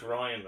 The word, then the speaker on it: Rian: It's pronounced Ryan, but I don't know why.